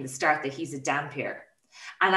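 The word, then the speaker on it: dhampir: And they also, yeah, Dampier was mispronounced as Dunpeel.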